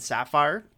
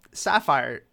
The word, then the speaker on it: sapphire: And sidebar, just Sapphire, that's the dumbest spelt word I've ever seen. I hate that there's two P's. What word has two P's but you don't pronounce one of them?